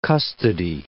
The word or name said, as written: custody